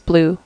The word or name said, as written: blue